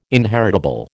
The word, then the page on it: inheritable: American English